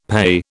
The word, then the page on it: PEI: British English